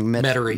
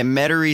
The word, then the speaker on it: Metairie: Metairie. – Yeah, Metairie Cemetery.